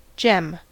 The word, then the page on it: gem: American English